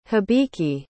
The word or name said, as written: habiki